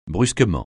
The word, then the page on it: brusquement: francais